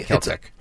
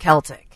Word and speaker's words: Celtic: Other dog names that are very popular in 2023 are, I guess we would call it, I don't know if it's Celtic or Celtic, but either way. – Celtic. – It's Celtic, okay. – Technically, it's pronounced Celtic. – Yeah, like you go to like a Celtic festival, but you know, you have the Boston Celtics. So that's why I was wondering which way they pronounce it. But that's up 364%. And the name Trey is up 222%. T-R-A-E.